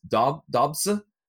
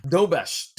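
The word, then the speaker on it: Dobeš: Jonas Dobse? – Dobesh.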